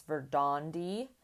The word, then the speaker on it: Verdandi: I've been mispronouncing his publishing name. It's Verdandi. He kindly pointed that out to me. Anyway, it's spelled differently than how it sounds, but that's a whole other issue.